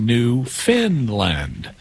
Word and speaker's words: Newfoundland: It's spelled Newfoundland, but it's pronounced Newfinland, even though it's not located in Scandinavia.